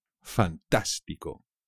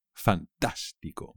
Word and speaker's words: fantástico: Fantástico. También es fácil, pero no os olvidéis del acento en la segunda A. Fantástico.